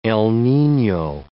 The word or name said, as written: El Nino